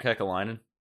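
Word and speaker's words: Kekäläinen: Oh, Adam's shaking his head at your pronunciation of Kekalainen, though. – Is it bad? Did I botch that? – No, you did. You did well.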